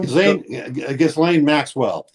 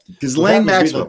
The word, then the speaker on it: Ghislaine Maxwell: Ghislaine Maxwell. – Ghislaine Maxwell.